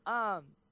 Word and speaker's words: Waxahachie: So, on November 18th and 19th, the first ever Waxacon, happened in, I'm sorry if I butcher this, Waxahachie, Texas? I'm guessing it's Waxahachie. That's how it's spelled. – Waxahachie.